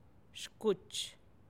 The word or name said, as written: xkutx